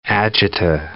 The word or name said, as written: agita